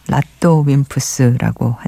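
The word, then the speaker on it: RADWIMPS: 라드웸프스 음, 뭐 일본식 발음으로 그런데요. 라또 웸프스라고 한대요.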